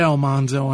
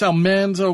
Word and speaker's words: Almanzo: We don't say Almanzo on this show, sir. – Oh, that's true. It was Manly, so Manzo. And I think that's the actual pronunciation. Unless you ask it is. – He goes Almanzo, right? Dean says, I think. – Yeah, it's Almanzo. According to Dean Butler, it's he's made the record straight, and I've been trying my best to make it a fact.